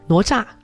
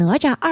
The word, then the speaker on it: 哪吒: 哪吒。 – 您再说一下那名字。 – 哪吒。 – 这是台湾的发音对吗？ – 诶，我就是这样念错了吗？ – 哪吒。 – 哪吒 OK 好。 – 你知道有一些方言叫拿抓，都有各种发音都有，反正很好玩。